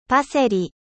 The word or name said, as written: パセリ